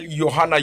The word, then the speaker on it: Yohanna: My name is Daniel Yohanna. Yohanna is spelled Yohanna.